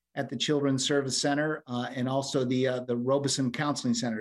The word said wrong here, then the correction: Robinson: Did I say it right? Robeson? – Robinson Counseling Center. – Robinson, sorry.